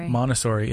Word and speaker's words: Montessori: Is that how you say it? – Montessori. – Yep. Nailed it.